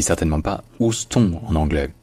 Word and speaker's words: Houston: Effectivement, en français, on est tenté de lire ça Houston parce que c'est littéralement comme ça que ça se lirait, selon l'orthographe française. Mais en anglais, c'est Houston. Houston.